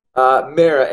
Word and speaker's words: Mara: Mara. M-A-R-A. Mara.